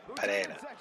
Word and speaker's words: Pereira: Like, for instance, she's from a town in Colombia called what we would say is Pereira. Pereira. – Yeah. – But her pronunciation of it, and it's her fucking town that she grew up in, it's Pereira.